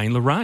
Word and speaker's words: Lorraine: And so she just decided in the spur of the moment to call Lorraine. – Because it rhymes with pain, and that's what happens when you're having babies.